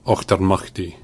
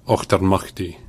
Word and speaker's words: Auchtermuchty: Ochtermachty. – Nailed it. – Ochtermachty.